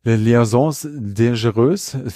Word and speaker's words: Les Liaisons Dangereuses: Dan Silver hatte ihn als Schurken schon in der Broadway-Version von Les Liaisons Dangereuses.